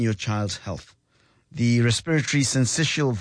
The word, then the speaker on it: syncytial: Pronounce it as syncytial virus.